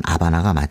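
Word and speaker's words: Habana: 어, 스페링은 H가 있습니다만 발음은 아바나가 맞죠.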